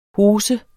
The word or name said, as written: hose